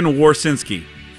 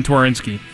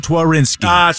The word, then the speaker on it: Twarinski: Carson Warsinski. – No, it's actually pronounced. It's Carson Twarinski. – Ah, you pronounce the T? – Yes. – Son of a gun. Damn it. – Carson Twarinski.